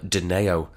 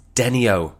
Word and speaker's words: Denio: I used to call them Deneo, but what a surprise, I was mispronouncing it. It's Denio. Denio, D-E-N-I-O, named after a ball game they used to play when they were kids on the streets of Liverpool.